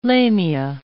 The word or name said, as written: lamia